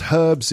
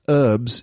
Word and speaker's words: herbs: Okay, herbs, pronounced herbs in British English and pronounced herbs in American English.